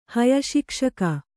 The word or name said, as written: ಹಯಶಿಕ್ಷಕ